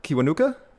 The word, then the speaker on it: Kiwanuka: Michael Kiwanuka. Kiwanuka? I'm sure I'm pronouncing that right, hopefully. – That's right.